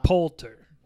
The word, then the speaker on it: Poulter: I don't know how you say his last name, but it sounds like Poulter. – That is how you say it. – Yeah, I was going to say, you pronounce it perfectly.